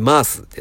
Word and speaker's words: MaaS: で、続きましてですね、えー、マースですね。えー、マース、えー、 MAAS と書いてるマース。